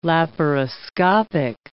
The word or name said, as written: laparoscopic